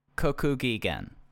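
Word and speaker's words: Kokugigen (国技館): This is the Revolution Rumble 1994 in Ryogoku, Kokokigaden. I always miss up pronounce it. It's at Sumo Hall. Sorry. – I can't believe people just don't say Sumo Hall. It's the only Sumo way Hall. I'll ever refer to it. – Kokugigen. There we go.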